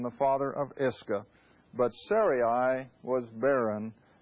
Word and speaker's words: Sarai: The name of Abram's wife was Sarai, and again I'm going to use the pronunciation as it's spelled.